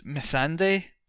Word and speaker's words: Missandei: Missandei from Game of Thrones.